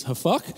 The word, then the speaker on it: hafak: This word overthrown is the Hebrew word hafak. Let's say that together, hafak. And I'm not going to make you say it how I think it's actually pronounced, which is hafuk, but we will just not go there.